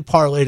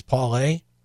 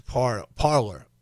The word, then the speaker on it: Parler: It's actually Parler. The French pronunciation is Parler. And to Parler is to sit and talk and talk things out. But somehow, because we're dumb Americans, it got changed to Parler.